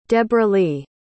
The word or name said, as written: debralee